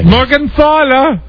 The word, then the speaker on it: Morgenthaler: And how about the way that Arnold pronounces her name? The German way. – Morgenthaler. – That's how you pronounce the name.